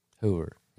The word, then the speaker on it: Hoover: He keeps talking about Herbert Huber. Herbert. Yeah, did I say that right? – Hoover, yeah.